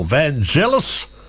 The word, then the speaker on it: Vangelis: John and Vangelis, or Vangelis, if you like to pronounce it that way, and I'll find my way home.